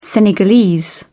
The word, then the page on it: senegalese: British English